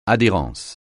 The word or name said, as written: adhérence